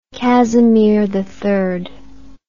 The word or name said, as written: Casimir III